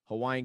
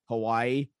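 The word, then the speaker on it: Hawaii (Hawaiʻi): Hawaiian culture, even pronouncing the names right? Because most people just say Hawaii, but it's actually, if I'm understanding correctly, it's Hawaii. There's like an apostrophe there, right? – Wait, how'd you say it again?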